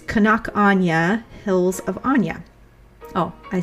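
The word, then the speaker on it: Áine: And this spelling is A-I-N-E. And no, it's not Aini. It's Anya.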